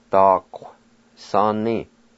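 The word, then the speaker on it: daquisani: The UI will be silent in this word, pronounced like this. Da, qua. Sa ni, da qu sa ni, da qu sa ni.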